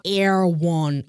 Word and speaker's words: Erewhon: Und zum anderen auf eine interessante Collab zwischen Balenciaga und einem Luxus-Lebensmittelhändler Air One. Ich weiß nicht, ob ihr schon mal davon gehört habt. Crazy Laden, auch richtig komisch geschrieben, E-R-E-W-H-O-N, wird aber Air One mhm. ausgesprochen. Mhm.